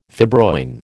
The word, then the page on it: fibroin: American English